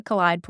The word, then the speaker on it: Kaleid: It comes from Kaleidoscope, but it's Kaleid. Is it the Kaleid Project? – It's the Kaleid Project.